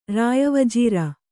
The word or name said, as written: ರಾಯವಜೀರ